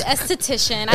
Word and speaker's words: esthetician: Did I pronounce that word right? Esthetician? – You were close enough. You tried. Esthetician. I know you practiced.